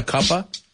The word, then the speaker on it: cuppa: De hecho, en, en inglés americano, if you say you want a cuppa, se puede, puede ser té o café, pero es C-U-P-P-A, cuppa. Cuppa. Yeah, we don't say the of. We say the of as a.